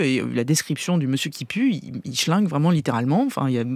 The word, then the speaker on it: Kipu: Et Monsieur Qui Pue, alors ça s'écrit K-I-P-U, mais enfin, c'est Monsieur Qui Pue, c'est un clodo, Monsieur Qui Pue.